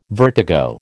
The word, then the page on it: vertigo: American English